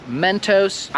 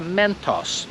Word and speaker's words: Mentos: I nie jestem pewny skąd jest Mentos, ale prawdopodobnie wy wymawiacie to tak samo jakby oni to wymawiali, bo wy mówicie Mentos. Ale po angielsku nie ma takiego dźwięku jak o. Zamiast o to jest o. Widzisz tą różnicę? Jak ja mówię o i o?